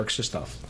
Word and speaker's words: Krzysztof: Okay, I like Christoph, but actually it's either Christopher or Krzysztof.